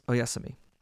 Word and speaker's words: oyasumi: So If you're saying goodnight to someone in Japanese, it's oyasumi.